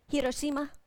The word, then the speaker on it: Hiroshima: Did I say it right, honey? My husband speaks. Say? Hiroshima. He speaks Japanese.